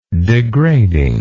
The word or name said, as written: degrading